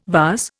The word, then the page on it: vase: Indian